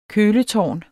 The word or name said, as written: køletårn